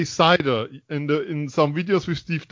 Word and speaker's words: Seider: you said Seider. So there's already a progress. – Yes. I wasn't sure which way was the proper pronunciation. And so, when Steve Yzerman called his name, he said Seider.